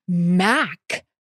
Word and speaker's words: Mack: What about Mack Stoll? And we were like, oh, rhymes with Jack. Same idea.